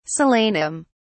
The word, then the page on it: solanum: British English